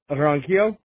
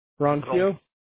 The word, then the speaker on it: Ronquillo: Ronquillo? Ronquillo? – How do you spell that? R-O-N? – Q-U-I-L-L-O. – Q-U-I-L-L-O.